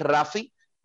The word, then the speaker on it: Raphy: Eh, como mi nombre real, que es Rafi, R-A-P-H-Y, Rafi Palacio, sin S al final, R-A-P-H-Y, Palacio, Rafi Palacio, o como el Cigal de la salsa, el seagal.